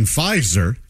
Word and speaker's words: Pfizer: I can get misspelling Pfizer. – Yes. – Yes. – Yeah. Moderna. – It was a silent P.